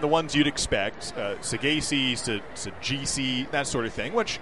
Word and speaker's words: Saggese: Him and Thomas Sajacy, asked the same thing. You'd think Sajacy would have gotten worse. – Yeah. – Because S-A-G-G-E-S-E, like, you're looking at that. Oh, how do you say that?